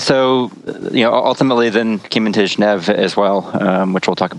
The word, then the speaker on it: Geneve: So I pronounce it Geneve, but I think I'm the only person that does, at least for English speakers. So the history was we named it Geneve because it was supposed to be kind of a coming together and a meeting place in Geneva, Switzerland. And Geneve is the original French pronunciation, but pretty much all the English speakers call it Geneve.